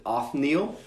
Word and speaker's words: Othniel: So first we've got, I think you pronounce it Othniel. Does that sound right? – Yep.